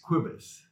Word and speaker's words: Cobus: Well, the right way my wife would say is Cobus. It's actually short for Jacobus, which is Dutch, but I'm from South Africa.